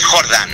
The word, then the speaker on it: Jordan: ¿Cómo pronuncias, Jordan o Jordán? – Pues mira, mi, apellido real es Jordan.